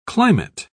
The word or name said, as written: climate